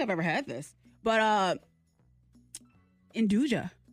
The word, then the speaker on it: nduja: The correct pronunciation is Andouille. Andouille. – Okay. This is a spicy pork sausage. In the pork family. Here it comes. Handing her the sausage. – Okay. N-D-U-J-A. – Yes, that's how it's spelled. N-D-U-J-A.